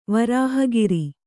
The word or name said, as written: ವರಾಹಗಿರಿ